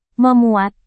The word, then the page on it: memuat: Indonesian